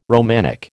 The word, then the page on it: Romanic: American English